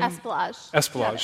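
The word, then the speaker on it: Espelage: Dorothy Esplanade. Am I mispronouncing her name? – Esplanade.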